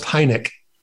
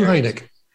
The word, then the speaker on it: Heinecke: But it's so my last name is pronounced Heinecke. – Heinecke. – Yeah, Stu Heinecke.